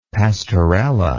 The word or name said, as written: pasteurella